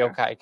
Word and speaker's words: Yokai: And Yokai coming. – Yeah. – Did I say it right? – Yeah.